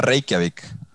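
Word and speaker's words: Reykjavík: Se pronuncia Reykjavik.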